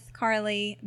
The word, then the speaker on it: Carly: So um, on Instagram, it's Smith I believe it's Smith Carly B.